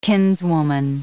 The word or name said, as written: kinswoman